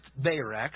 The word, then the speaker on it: Barak: It is pronounced Barak. I did look it up.